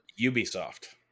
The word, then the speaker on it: Ubisoft: Scott Pilgrim was an Ubisoft game, or Ebisoft, or however they say you pronounce it. – Ubisoft.